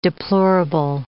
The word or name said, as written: deplorable